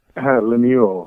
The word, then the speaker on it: Lemuel: For anyone who hasn't caught it yet, maybe tell us just a little bit about the show and then your character, Lemuel. Is it Lemuel? Am I saying that right? – Lemuel.